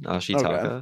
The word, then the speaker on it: Ashitaka: I think that was pretty good, Ashitaka. Okay. – Yeah, that was more just my warning for the rest of the episode. So, the young warrior, Ashitaka, is cursed by a demonized boar god, pushing him to seek a cure in the Western lands.